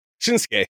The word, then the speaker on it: Shinsuke: It was the Usos, Jay Uso and Jimmy Uso against Shinsuke Nakamura and Rick Boogs. Shinsuke. No, you don't pronounce second U. I don't know why.